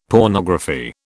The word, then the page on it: pornography: British English